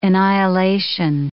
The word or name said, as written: annihilation